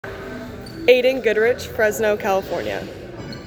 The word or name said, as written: Aidan Goodrich